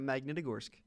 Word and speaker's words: Magnitogorsk: Just think of Magnet, and then you're good. You got Magnitogorsk.